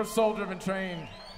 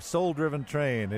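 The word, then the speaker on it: Sol Driven Train: Right here on KVNF Live, Soul Driven Train. If you're looking for them online, that's spelled S-O-L, as in solar or the sun.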